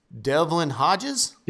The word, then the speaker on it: Devlin Hodges: Now we got Devlin Hodges.